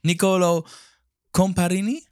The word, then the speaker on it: Nicolo Comparini: Because one of our main guests today is, um, uh, uh well, English speaker, Nicolo Comparini.